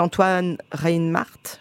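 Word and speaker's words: Antoine Reinhartz: On n'a pas parlé de Arnaud Valois, j'aime beaucoup, et Antoine Reinhardt, j'ai bien prononcé, c'est ça.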